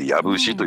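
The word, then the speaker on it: 養父市: 養うに地と書いて藪市というふうに読むんですけれども、はい、ここはですね国家戦略特区農業特区に指定されてるところなんですよ。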